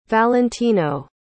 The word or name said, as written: valentino